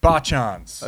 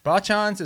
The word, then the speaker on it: Bachan's: Bachan's. – Okay, I'm going to write this down. – Bachan's is all about, it's a family. – Spell it for me. – B-A. – B-A-C-H-A-N apostrophe S.